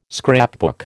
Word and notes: scrapbook: American English

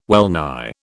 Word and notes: wellnigh: British English

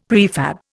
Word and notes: prefab: Indian

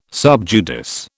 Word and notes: sub judice: British English

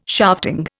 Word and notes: shafting: Indian

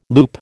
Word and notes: loop: American English

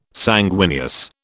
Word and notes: sanguineous: British English

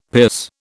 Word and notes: pierce: British English

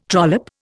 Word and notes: trollop: Indian

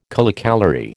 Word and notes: kilocalorie: American English

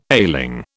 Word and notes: ailing: British English

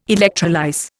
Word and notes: electrolyse: Indian

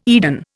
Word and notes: Eden: Indian

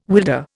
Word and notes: wilder: Indian

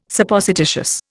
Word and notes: Supposititious: Indian

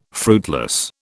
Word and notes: fruitless: British English

